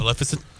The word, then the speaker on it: Maleficent: Maleficent. I can never say her name. – Maleficent? – Yes.